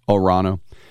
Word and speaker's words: Toronto: Or as they say, you don't pronounce the T, the second T, Orano. You don't pronounce the second T up there, Toronto.